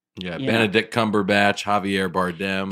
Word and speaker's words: Bardem: That was in the rehearsal script that I was, and I kept saying Javier Bardem. Bardem, I was saying it wrong. My wife was like, it's them, like Democrat, Bardem.